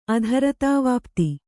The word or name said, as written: ಅಧರತಾವಾಪ್ತಿ